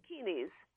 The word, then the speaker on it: Dakinis: Dakinis.